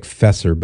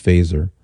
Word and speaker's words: Feser: So Faser, his name is spelled F-E-S-E-R. It looks like Fesser, but it's pronounced Faser, as in set your phasers to stun.